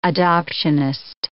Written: adoptionist

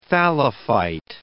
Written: thallophyte

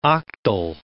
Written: octal

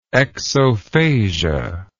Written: exophasia

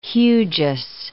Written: hugeous

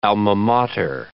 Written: alma mater

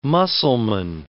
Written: Mussulman

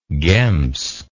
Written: gems

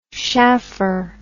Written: shaffer

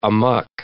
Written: amuck

